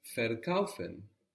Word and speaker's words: verkaufen: To sell is verkaufen. So we take this ver, this V-E-R, it's pronounced like F, and we get to sell from to buy.